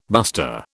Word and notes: buster: British English

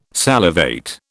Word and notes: salivate: British English

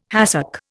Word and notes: hàcek: Indian

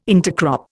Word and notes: intercrop: Indian

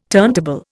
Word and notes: turntable: Indian

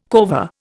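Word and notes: corvée: Indian

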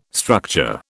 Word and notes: structure: British English

